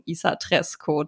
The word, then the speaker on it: ISA-TRAESKO: Und meine erste Frage ist an dich, habe ich das richtig ausgesprochen, Isa Tresco? Da war ich mir nämlich sehr unsicher in der Vorbereitung. Ja, lieben Dank dir, hast du perfekt ausgesprochen.